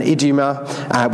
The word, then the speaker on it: Idumea: Jesus withdrew with his disciples to the sea, and a great crowd followed from Galilee and Judea and Jerusalem and Idumea, which is how you say it, and from beyond the Jordan and from Tyre and Sidon.